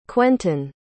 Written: quenton

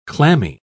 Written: clammy

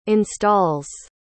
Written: installs

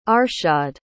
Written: arshad

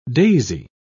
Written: daisy

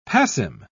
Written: passim

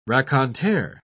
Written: raconteur

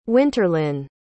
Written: winterlynn

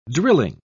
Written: drilling

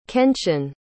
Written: kenshin

